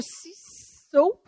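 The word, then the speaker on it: CISSSO: Alors, rappelons-nous là, que là, présentement, c'est le CISO, parce qu'il y en a trois S. Mm-hmm. Fait que faites bien des S dans votre prononciation.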